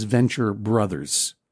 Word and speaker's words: Venture Bros: So I reached him at a reasonable hour, and I said, even though it is spelled Venture Bros, do you say Venture Bros or Venture Brothers? And according to the co-creator, and I'm sure that his co-creator, Doc Hammer, would support him, it is Venture Brothers.